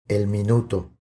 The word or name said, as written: El minuto